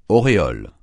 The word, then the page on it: auréole: francais